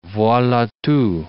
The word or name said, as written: voila tout